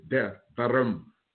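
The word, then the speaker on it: Devarim (דברים): If it did, Cartrell taught me the correct pronunciation and chapter 20. So get your maps out, go to Deuteronomy. But the real name for that book is Devarim.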